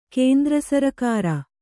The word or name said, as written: ಕೇಂದ್ರಸರಕಾರ